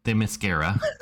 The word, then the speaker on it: Themyscira: And Wonder Woman, Princess of Themyscira. Did I pronounce it right that time? – Well, yes, that's impressive.